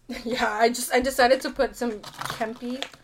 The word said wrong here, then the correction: Kewpie: What is what? Kewpie? Oh my god, I'm K-E-W-P-I.